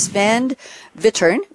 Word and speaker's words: Wittern: It is Sven Wittern, W-I-T-T-E-R-N.